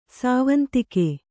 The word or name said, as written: ಸಾವಂತಿಕೆ